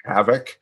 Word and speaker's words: HVCC: which is HVCC, which is pronounced havoc, because that's what it brought to the industry.